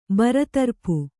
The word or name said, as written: ಬರತರಪು